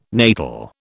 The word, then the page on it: natal: British English